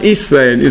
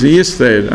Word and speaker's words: Yisrael: Rabbi Shalom gave a new birth certificate to Yaakov and Alam Shalom, and the name was Israel. It's not Israel, it's Yisrael. And also when you say Shema Yisrael, not Israel, you have to make sure you say it the Yud pronunciation, not Aleph.